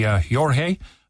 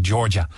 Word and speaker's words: Jorja: It's spelled J-O-R-J-A. That could be Jorge. But I think it's pronounced like Georgia. Georgia, J-O-R-J-A, yesterday at the C-U-M-H.